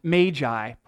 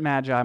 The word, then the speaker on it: magi: what I learned is pronounced magi properly. I've always said it magi my whole life, but bubble burst. Magi.